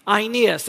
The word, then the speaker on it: Aeneas: There, Peter found a man named Aeneas, that's how it's pronounced in Greek, who had been bedridden eight years.